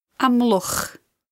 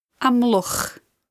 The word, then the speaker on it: Amlwch: Amlwch. Amlwch.